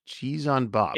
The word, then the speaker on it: Chisanbop: Cheese on bop. It's called. Okay. It's spelled C H I S A N B O P.